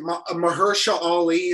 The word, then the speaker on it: Mahershala Ali: Mahershala Ali, is that how you say his name? – Remember. I believe that's correct.